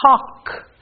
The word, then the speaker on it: talk: The next consonants are LK, where the L will be silent, as in talk. You can't hear the L.